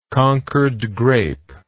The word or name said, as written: concord grape